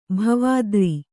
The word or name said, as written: ಭವದೂರ